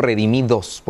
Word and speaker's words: Redimi2: Porque es la, en Latinoamérica la mayoría decimos redimidos por el 2, pero realmente en, Dominicana dicen el 2, lo pronuncian como dos, ¿verdad? Es como dos.